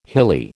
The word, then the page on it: hilly: American English